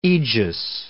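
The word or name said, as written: eglantine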